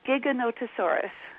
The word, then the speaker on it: Giganotosaurus: It's one of your dinosaur books, the, help me here, Gigantosaurus. Am I even pronouncing that right? – It's Giganotosaurus. – Totally wrong. Giganotosaurus. You know, when we were kids, this dinosaur had not been discovered yet.